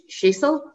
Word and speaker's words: Sissel: Now, her name is, it's pronounced Sissel.